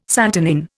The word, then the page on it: santonin: Indian